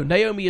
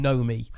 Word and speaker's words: Naomi: I still struggle to say the name Naomi, because apparently that's the incorrect way to say it. It's something like, oh no, Naomi is right. I spent a good 35 years of my life calling all people with that name Nomi.